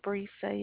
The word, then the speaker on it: Brisa: Brisa, yes, ma'am. – How do I spell that? – B-R-I-S-A.